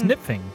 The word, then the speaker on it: Knipfing: K-N-I-P-F-I-N-G. – Nipfing? Knipfing. It's Nipfing. Right? – Nipfing. – Like Knife. Nipfing.